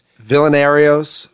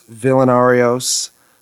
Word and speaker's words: Villanarios: Am I pronouncing it right? – Villanarios, Villanarios, Vile, Villain, all like the nicknames around it.